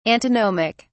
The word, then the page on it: antinomic: British English